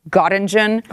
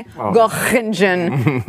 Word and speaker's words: Göttingen: After this one stop, I don't know how to pronounce it, Göttingen. – Oh, you nailed it. – Did I? Göttingen.